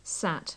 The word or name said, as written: sat